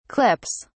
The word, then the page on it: clips: British English